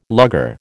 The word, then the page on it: lugger: American English